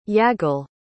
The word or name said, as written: yagil